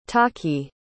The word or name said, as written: tahki